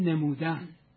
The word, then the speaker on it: نمودن: ولی من با توجه به اینکه اصل لغت رو مرحوم فرادشی نیموتن نوشته، دیگه معتقدم که فقط بگیم نمودن، به کسره بگیم، فتح و زمش غلط میشه.